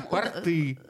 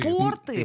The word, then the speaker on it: порты: Порты. Э, значит, смотрите. Порты. – Порты. Порты. Нет, порты. – Нет, смотри, порты. но при этом портов.